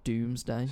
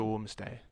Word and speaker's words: Domesday: It's spelled like D-O-U. It's not Doomsday. – So it's like Domesday or something. – Domesday.